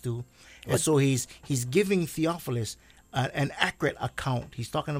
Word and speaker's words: Theophilus: most excellent Theophilus," how do you say that, Pastor? Theophilus, yeah.